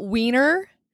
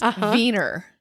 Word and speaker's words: Wiener: It's not wiener, it's wiener.